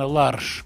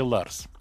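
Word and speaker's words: Lars: Я не знаю, какое датское произношение, наверное, Ларш, как в Швеции, там смягчено это, Ларш. Ну, неважно, для нас проще Ларс.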